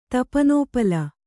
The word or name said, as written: ತಪನೋಪಲ